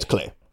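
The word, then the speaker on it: Klei: Is it Clay or Cly? – It's Clay. Rhymes with play, I've been told.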